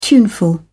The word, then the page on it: tuneful: British English